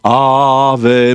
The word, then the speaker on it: Ave: And it's Reflections on the Names of God by Ava, A-V-A, Pennington. By the way, Ava, you know, like that could also be pronounced Ava, right? Like Ave Maria.